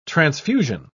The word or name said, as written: transfusion